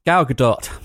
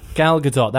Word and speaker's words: Gal Gadot: How do you pronounce it? – Gal Gadot. – Gal Gadot. That's what we're going with.